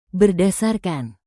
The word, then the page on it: berdasarkan: indonesian